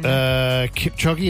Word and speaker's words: Kipchoge: Kipchoge, is that how I say his name? – Yeah.